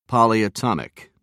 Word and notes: polyatomic: British English

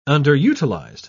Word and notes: underutilized: British English

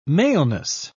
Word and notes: maleness: British English

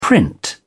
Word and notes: print: British English

